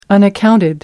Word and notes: unaccounted: American English

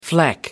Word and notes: fleck: American English

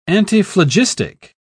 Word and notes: antiphlogistic: British English

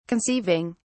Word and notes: conceiving: British English

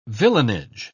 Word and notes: villeinage: British English

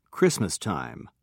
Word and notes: christmastime: British English

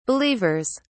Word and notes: believers: British English

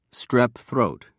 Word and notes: strep throat: British English

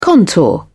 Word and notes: contour: British English

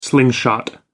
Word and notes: slingshot: American English

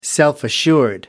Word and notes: self assured: American English

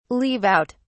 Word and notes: leave out: British English

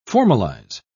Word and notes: formalise: British English

